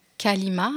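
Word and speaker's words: Kolyma: Il s'appelait Kalima, mais est-ce que Kalima, c'est Kolima Exactement. La Kolima, donc les... C'est la Kolima, je l'ai appelée volontairement Kalima avec le son A, parce qu'en russe, un O non accentué se prononce A.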